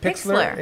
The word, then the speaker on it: Pixlr: Is that how you say it? – Pixlr. – Pixlr Express, which is P-I-X-L-R Express.